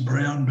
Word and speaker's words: Braun: Noted, well, he'd been a young designer at one stage when he joined Brown. – Yes. – People don't realise the name's pronounced Brown, but it's spelled B-R-A-U-N.